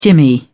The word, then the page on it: jimmy: British English